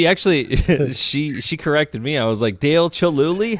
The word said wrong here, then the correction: Chihuly: Heck yeah, man. And she was like, it's Chihuly, Dad.